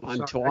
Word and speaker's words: Montauk: My brother will take us out there, but you got to pronounce it correctly. Dude, that U is a W. – Dude, I'm so sorry. – Montauk.